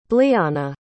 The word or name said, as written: blyana